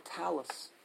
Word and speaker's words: talus: Arukum, the talus. How do you pronounce it? Talus bone? – Yeah, yes.